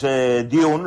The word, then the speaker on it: Dune: Bueno, pues Dune,